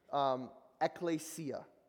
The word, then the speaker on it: ekklesia: ekklesia is the way you pronounce that word, ekklesia.